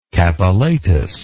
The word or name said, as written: capillatus